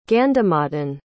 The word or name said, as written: gandhamadhan